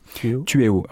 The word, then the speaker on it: Tueo: Tueo. Alors, ça s'écrit T-U-E-O.